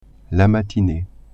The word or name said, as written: matinee